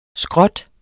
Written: skrot

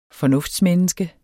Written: fornuftsmenneske

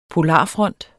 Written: POLARFRONTEN

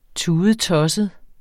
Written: tudetosset

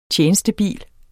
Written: tjenestebil

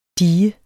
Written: die

